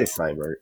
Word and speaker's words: Seibert: The Austin Seibert, is that how you say his name? Seibert? – I say Seibert, yeah.